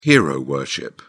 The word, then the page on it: hero-worship: British English